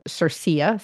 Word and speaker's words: CIRCIA: which is pronounced as its abbreviation, CIRCIA, C-I-R-C-I-A.